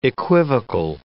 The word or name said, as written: equivocal